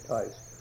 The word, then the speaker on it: Theis: And anyhow, She was a daughter of this O'Brien's, and her mother was a Tice, T-H-E-I-S, and they pronounce it Tice.